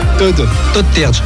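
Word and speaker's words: Todd Terje: C'est pointé numéro 4, Todd Terch. Je sais pas comment on prononce d'ailleurs le matou. Comment tu dis ça? Todd Terch. D'accord. On est au courant maintenant.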